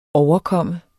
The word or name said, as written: overkomme